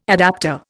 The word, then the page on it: adaptor: Indian